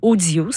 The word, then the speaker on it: OHdio: Vous nous trouvez notre page, La Mouvée, sur l'application gratuite Audio. Ça s'écrit O-H-D-I-O.